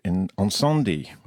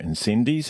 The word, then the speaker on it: Incendies: The first is called Encendi, or Incendies, as we like to pronounce it down here,